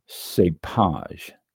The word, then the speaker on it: cépage: This is a French term for grape variety and it is pronounced cépage.